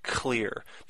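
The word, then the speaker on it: Xlear: It's spelled X-C. I'm sorry, it's not even a C. It's X-L-E-A-R. Clear.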